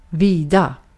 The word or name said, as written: vida